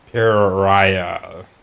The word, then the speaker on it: Pariah: Reese's Pariah. That's how you pronounce that word.